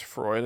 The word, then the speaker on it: Freud: Um, but I had a a big one that was a Freud. Is that how you say it? Fruit. I think it's Freud. I think you said it right.